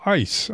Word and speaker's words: ICE: First of all, ICE, I-C-E. Pronounce it that way, you do the word that means frozen water.